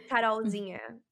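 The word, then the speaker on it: Carolzinha: Carol Zinha, right? Mm-hmm. So like little Carol, which is how you pronounce my name, like my nickname in Portuguese.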